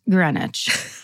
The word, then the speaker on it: Greenwich: And then in parentheses, it says, pronounced Greenwich.